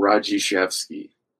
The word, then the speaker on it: Radziszewski: How do we pronounce your last name? I'm seeing it on the screen, and I'm like, I don't know how to say that. – Radziszewski. – I would have butchered that so bad. – Yeah, it took me to like fourth grade to like pronounce it the right way.